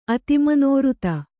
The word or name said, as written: ಅತಿಮನೋಋತ